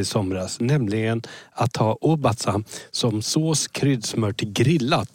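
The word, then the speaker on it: Obatzda: Jo, här ska vi se så att jag uttalar rätt. Obasta. – Obasta, precis.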